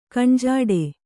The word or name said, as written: ಕಣ್ಜಾಡೆ